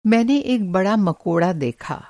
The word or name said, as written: मकोड़ा